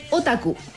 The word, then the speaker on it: otaku: ¿Y cómo se pronuncia? – Otaku. – Ah, ya. – Igual que como nosotros lo decimos.